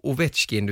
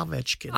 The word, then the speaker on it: Ovechkin: Och helt plötsligt skulle det inte vara Ovechkin utan det skulle vara Ovechkin, Ovechkin med ett A då.